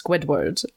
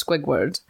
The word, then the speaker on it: Squidward: um, like last episode, it was revealed that I mispronounced Squidward as Squigward.